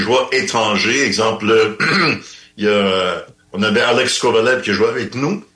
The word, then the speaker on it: Alex Kovalev: On avait tendance à y aller un petit peu à la russe, Alexei Kovalev, et lui a dit non, moi je travaille en Amérique du Nord, alors ça va être Alex Kovalev. C'est le nom que je vous demande de prononcer, et c'est exactement ce qu'on a fait.